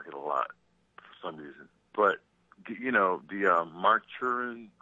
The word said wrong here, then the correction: Maruchan: I don't know how to pronounce that. Look at the label. – Oh, the Maruchan? – Maruchan.